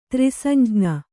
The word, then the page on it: ತ್ರಿಸಂಜ್ಞ: kannada